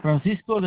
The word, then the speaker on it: Francisco: Francisco del Ángel Cardona.